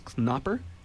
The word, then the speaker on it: Knopper: Okay, the second question was about Klaus Knopper. Is that the correct pronunciation? – Yeah.